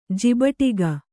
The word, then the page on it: ಜಿಬಟಿಗ: Kannada